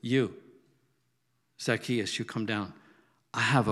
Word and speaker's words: Zacchaeus: Zacchaeus.